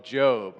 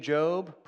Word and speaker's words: Job: I want to call it Job because it's spelled the same way, but it's the book of Job, right?